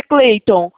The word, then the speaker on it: Clayton: É Clayton mesmo ou é Clayton? – Escreve Kratos, mas lê Cleito. – Ah, entendi. Se escreve assim então, Clayton, mas é Clayton.